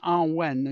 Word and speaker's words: Awen: which the Druids called Awen. which is pronounced Arwen, and you can sing it.